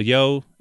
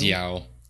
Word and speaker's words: Michelle Yeoh: Big actors, Michelle Yeoh. Is that how you say it? Yeoh? – I thought it was Yao.